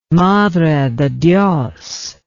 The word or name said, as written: madrededios